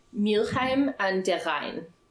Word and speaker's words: Mülheim an der Rhein: So it would be pronounced Mülheim an der Rhein. That's how you would say that.